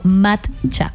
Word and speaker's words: matcha: No, matcha, con T.